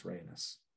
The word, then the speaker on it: Rhaenys: Rhaenys is my favorite character in Fire and Blood. One of my favorite characters in the whole series. And yes, Eve Best is great. Also, it's Rhaenys.